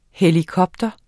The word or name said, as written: helikopter